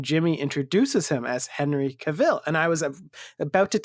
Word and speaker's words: Cavill: And then a video of him pops up and he goes, Cavill, just like travel, which is just what you said.